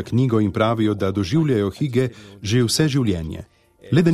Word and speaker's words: hygge: So what is hige, or how you say hike hige? – So we pronounce it hygge, and it's a Danish concept that basically means the art of creating a nice atmosphere.